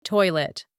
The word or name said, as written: toilet